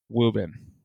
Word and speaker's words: Woburn: You know, why is it spelled Woburn but pronounced Woobin? – So graduated in 2002.